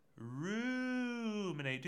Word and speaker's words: ruminate: The anger, the frustration, we ruminate. That's a fun word. Say it. Ruminate. Ruminate. Do that long U.